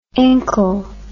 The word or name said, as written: incle